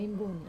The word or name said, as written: imboni